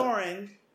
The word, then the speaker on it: Doran: Marcella goes to visit Jaime because because uh, uh, uh, Prince Duran's guard says, "Okay, look, Doran." – Well, he pronounced it Duran.